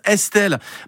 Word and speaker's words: Estl: Bah, c'est écrit E-S-T-L sur mon truc, mais ça se prononce Estelle.